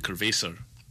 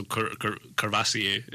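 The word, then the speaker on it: Courvoisier: I used to pronounce Cervasi Curvesor. – Cerveza. – No, Cerveza is a beer. – Yeah, I know. – In Spanish, but Curvesor, the brandy. – Oh. – So Cervasier is like...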